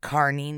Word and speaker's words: Kearny: And apparently, they pronounce it Kearney, New Jersey, but it's spelled Kearny, so that's how we're saying it.